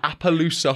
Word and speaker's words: Appaloosa: Apparently, the Appaloosa horse is the Idaho state animal. Did I say that right? Maybe. – You did.